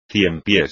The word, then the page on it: ciempiés: espagnol